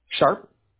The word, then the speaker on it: Sharp: Sharp.